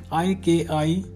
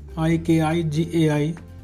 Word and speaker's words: ikigai: In Japan, millions of people have ikigai, pronounced as ik-i-gai. So that's how it is pronounced, ik-i-gai, a reason to jump out of bed each morning.